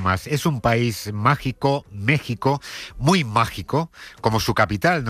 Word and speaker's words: México: Vamos, porque lo vemos venir, está en México, no en México. Se escribe con X, pero se pronuncia con J, ese país que no existió hasta que llegaron los españoles, según Ayuso.